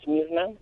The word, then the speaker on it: Myrna: Myrna. Either is fine. No. How do you say it? – Well, in Spanish it's Myrna, but in English it's Myrna, so that's why I take either or.